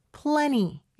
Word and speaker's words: plenty: 참고로 plenty는 plenty 이렇게 발음할 수도 있습니다. T 발음은요, N 다음에 올 때는 생략되는 경우가 많아요.